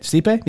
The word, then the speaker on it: Stipe: Stipe? – Stipe, yeah.